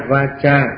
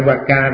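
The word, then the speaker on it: vācā: À, thấy chưa, dù là sam-ma-sang-cáp-ba xong, bây giờ tới chánh ngữ sam-ma-va-cha, chữ C-A nó đọc là cha va-cha chứ không phải va-ca đâu.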